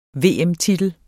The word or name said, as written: VM-titel